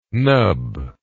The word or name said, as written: knub